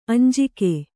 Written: ಅಂಜಿಕೆ

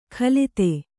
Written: ಖಲಿತೆ

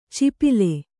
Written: ಚಿಪಿಲೆ

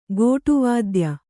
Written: ಗೋಟುವಾದ್ಯ

